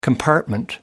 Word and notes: compartment: American English

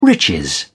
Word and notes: riches: British English

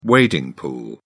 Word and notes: wading pool: British English

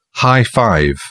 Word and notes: high-five: British English

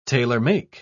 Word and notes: tailor-make: British English

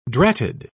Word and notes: dratted: British English

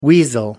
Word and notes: weasel: American English

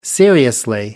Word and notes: seriously: American English

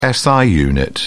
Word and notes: si unit: British English

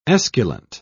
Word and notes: esculent: British English

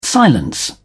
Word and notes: silence: British English

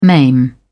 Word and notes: maim: British English

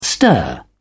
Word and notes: stir: British English

